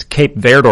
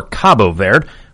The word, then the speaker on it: Cabo Verde: It's Cape Verde or Cabo Verde.